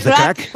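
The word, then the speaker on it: craic: What's the crack? – What's the crack? Es como el how are you, pero en irlandés, ¿vale?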